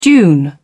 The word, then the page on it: dune: British English